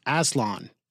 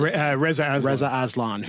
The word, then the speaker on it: Aslan: That was just before Aslan. Is the way we pronounce it. – Reza Aslan.